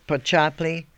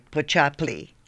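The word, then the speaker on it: Počaply: It's, can you spell that, yes, Pochaply, P-O-C-A-P-L-I, Pochaply.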